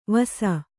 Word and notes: ವಸಾ: Kannada

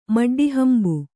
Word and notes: ಮಡ್ಡಿಹಂಬು: kannada

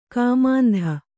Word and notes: ಕಾಮಾಂಧೆ: kāmāndhe

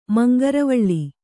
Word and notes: ಮಂಗರವಳ್ಳಿ: mangaravaḷḷi

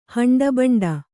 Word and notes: ಹಂಡಬಂಡ: haṇḍa baṇḍa

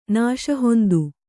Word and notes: ನಾಶಹೊಂದು: kannada

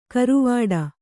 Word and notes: ಕರುವಾಡ: karuvāḍa

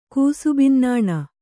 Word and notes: ಕೂಸುಬಿನ್ನಾಣ: kannada

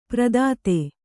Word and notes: ಪ್ರದಾತೆ: pradāte

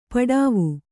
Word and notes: ಪಡಾವು: Kannada

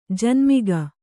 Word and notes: ಜನ್ಮಿಗ: kannada

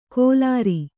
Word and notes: ಕೊಲಾರಿ: kolāri

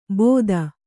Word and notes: ಬೋದ: kannada

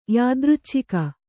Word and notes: ಯಾದೃಚ್ಛಿಕ: kannada

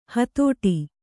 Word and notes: ಹೊದಟು: hodaṭu

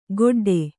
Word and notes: ಗೊಡ್ಡೆ: kannada

